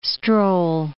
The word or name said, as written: stroll